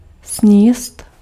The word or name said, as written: eten